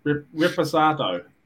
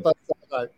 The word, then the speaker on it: Reposado: How do you say it? – Reposado. – Reposado. – Well done. Reposado.